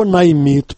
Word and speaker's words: наймит: «Уважаемый американский наймит». По-русски слово «наймит» произносится с ударением на последнем слоге. Вы же, читая когда-то мое письмо, произнесли его по-украински, то есть с ударением на первом слоге.